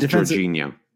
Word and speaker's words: Jorginho: There's also talk that uh, Jorginho, who's a defense, I don't know if I'm pronouncing that correctly. – I think uh, it's Jorginho. – Jorginho, thank you.